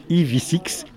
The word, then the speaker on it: EV6: qui va s'appeler EV6, qu'il faut prononcer EV6,